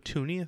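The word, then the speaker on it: Thuney: AJ Green, like I mentioned before, Hunter Henry, Joe Tooney, I think that's how you pronounce it, not the TH.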